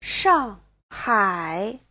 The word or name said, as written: shang4hai3